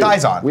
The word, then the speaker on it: Dizon: It rhymes with the mustard. – Dijon. – Yeah. – And Dijon. – It's our boy, New. – Dizon.